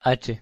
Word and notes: h: Spanish

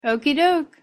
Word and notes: okeydoke: English

